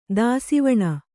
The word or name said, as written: ದಾಸಿವಾಣ